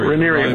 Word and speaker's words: Rhaenyra: Yeah, Rhaenyra rhymes with diarrhea.